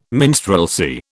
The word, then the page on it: minstrelsy: British English